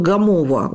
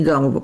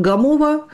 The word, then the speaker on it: Гамово: Но дело в том, что вот Пермский край, когда мы говорим Пермский край, я сразу вспоминаю поселок Гамово, ударение стоит именно там, не Гамово, поселок Гамово,